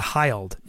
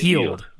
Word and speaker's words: Hield: And I know who Ben Simmons is and Brandon Ingram are and Buddy Hield. So, and Chris Dunn. I know a lot of these guys. – Buddy Hield, so, not Hyled. It's Buddy Hield.